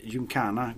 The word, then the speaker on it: Gymkhana: Och Gymhakan, det är alltså Gymkhana Golf Club, okay. Vi menar det. Vet du vad Gymkhana är för någonting? – Nej, det vet jag faktiskt inte. – Men Gymkhana är i alla fall sån här lektävling med hästar.